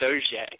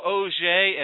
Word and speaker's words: Auger: It's actually Ogier. It's one Ogier. – Of those it's a crazy French name that sounds nothing like how it's spelled. It's spelled Auger, but it's pronounced Ogier.